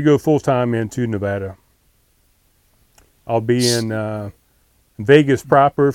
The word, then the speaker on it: Nevada: Nevada. How do you say it? – Nevada. – I think the locals say Nevada.